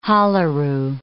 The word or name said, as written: haleru